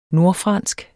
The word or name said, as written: nordfransk